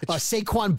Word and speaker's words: Saquon: I write it phonetically so that he could see S-A-Y-K-W-A-N, Saquon. – Because the most important thing is pronouncing it right on the air. – Right, and I'll put S-A-Y in capitals so he knows the accent Saquon. – Right. – Right. S-A-Q-C-U-O-N or is S-A-Q... Right. – Yeah. – U-A-N.